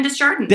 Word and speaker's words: Desjardins: Desjardins, Desjardins. He's got such oh, a Desjardins, Evan Desjardins. – Yes, you say the S.